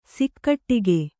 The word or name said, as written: ಸಿಕ್ಕಟ್ಟಿಗೆ